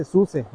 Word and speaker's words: SUSE: SUSE es una compañía alemana y el acrónimo de SUSE significa Software und System Entwicklung. En español es desarrollo de software y de sistemas. Y el alemán y el español se pronuncian igual, entonces se dice SUSE, no SUS.